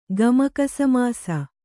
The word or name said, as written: ಗಮಕಸಮಾಸ